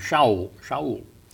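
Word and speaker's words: Shaul (שאול): His Hebrew name was Saul. The way they would pronounce it would be Shaul.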